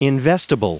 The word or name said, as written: investable